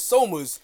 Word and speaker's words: Somers: And I'm like, what is the correct pronunciation? – Right. – First of all, it's Somers.